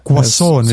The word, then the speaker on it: croissant: Croissant vist peab ütlema croissant, croissant.. Mm. ilma R-ita Sa.